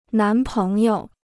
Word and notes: 男朋友: nán péng you

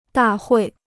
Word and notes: 大会: dà huì

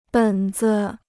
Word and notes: 本子: běn zi

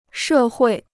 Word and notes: 社会: shè huì

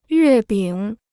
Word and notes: 月饼: yuè bǐng

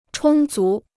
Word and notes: 充足: chōng zú

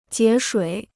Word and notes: 节水: jié shuǐ

